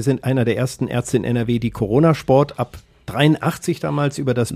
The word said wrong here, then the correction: Coronarsport: Nicht Corona-Sport, das war. – Coronarsport. Coronar, so Corona, spricht man das aus. Ja, bloß nicht. Hörte sich so ähnlich schon an wie Corona. – Ich korrigiere. – Coronarsport, ja.